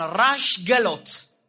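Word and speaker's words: رش گلوت، (ראש גלות): رأس الجالوت به بزرگ یهودیان میگن، رش گلوت، عبریش رش گلوته، رش همون رأسه، یهودی ها سین و شین خیلی تلفظ میکنن، گلوت عربا گه ندارن بهش گفتن جالوت، یعنی بسیار دقیقه.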